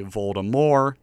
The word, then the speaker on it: Voldemort: She recently announced that people have, apparently, according to her, been pronouncing Voldemort's name wrong for years, stating it should be Voldemort, that you don't pronounce the T.